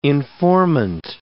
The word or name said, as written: informant